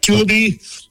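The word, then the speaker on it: tullibee: I want to touch back into something you mentioned earlier, the tulipy. Am I saying that right? – Yep, tulipy, or sometimes they're known as a whitefish.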